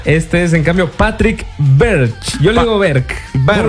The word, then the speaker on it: Berge: Berge se escribe, ¿no? Berch.